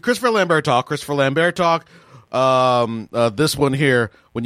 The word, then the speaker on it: Lambert: I looked up how do you pronounce Christopher Lambert. I'm pretty sure it's Lambert.